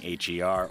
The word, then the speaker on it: H.E.R.: Now we've got Skip Marley coming up after that, featuring H.E.R. or Her, as it would properly be pronounced.